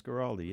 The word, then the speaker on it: Guaraldi: He studied with Colin Bailey, a drummer of Vince Guaraldi. Did I pronounce that one right? – Vince Guaraldi, yes.